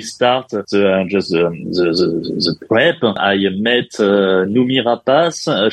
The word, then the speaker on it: Noomi Rapace: Noomi Rapace, is that how you say it? – Yes, exactly.